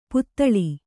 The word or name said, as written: ಪುತಳಿ